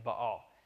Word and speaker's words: Baal: I've always grown up saying Baal, but the correct pronunciation is Baal.